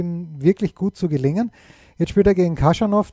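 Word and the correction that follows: Khachanov: der ja Katschanov, wie er, glaube ich, richtig ausgesprochen wird, fünf Sätze gegen Korda.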